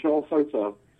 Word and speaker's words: Charl Goussard: Brian, it's Charl Goussard.